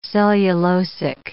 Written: cellulosic

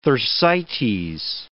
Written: Thersites

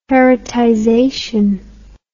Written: heritization

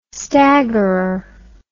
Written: staggerer

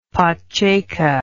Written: pacceka

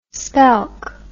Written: spelk

